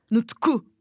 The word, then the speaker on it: Nutku: Nasıl okunuyor ya? Nutku. Nutuku. – Te, telaffuz Nutku.